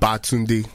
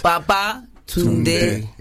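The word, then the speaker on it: Babatunde: So say it like you're saying come back or returned. – Baba Tunde. – Yeah. It's like a song.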